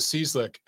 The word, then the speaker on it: Cieslak: us against uh, Cieslak, I think is the way it's supposed to be pronounced. But here's the thing with Cieslak, and I talked about this uh, Monday on TNC.